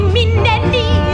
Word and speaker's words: Minnelli: C'est M, I, puis deux N, puis I, deux L et I. Les douzaines et deux blés font par nous. Et encore deux L, puis les derniers. Prononcez cette si Minnelli, Liza Minnelli.